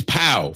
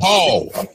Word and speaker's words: Pau: Dirk Nowitzki, Tony Parker, Pau Gasol, y'all. His name is Pau. For everybody listening to the Some Dude Show, it is not Paul.